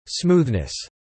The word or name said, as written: smoothness